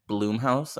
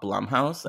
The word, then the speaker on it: Blumhouse: One time I was on a podcast with Joe Lipsett and I pronounced it Bloomhouse and not Blumhouse, and he served me my tush and I deserved it.